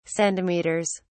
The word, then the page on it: centimeters: British English